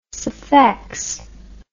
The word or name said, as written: sphex